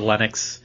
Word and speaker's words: Linux: Not Annie Linux, but Linux. Because Steam wants to be your OS. Steam OS is going to be based off of Linux.